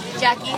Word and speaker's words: Jacki: Jackie. – How do you spell that? – J-A-C-K-I.